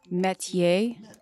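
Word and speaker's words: métier: Metier. Like M-E-T-Y-A-Y. – Metier. Am I not saying it? – You said metier. – No, I said metier.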